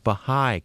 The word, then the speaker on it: Baha'i: Connie Wilson, you're a member of the local, and I hope I'm pronouncing this correctly, Baha'i community.